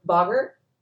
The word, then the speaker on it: Boggart: But I know one thing that really stood out to me when it came to relating to her was her Boggart. I don't know if I said that right. – Yeah, you did.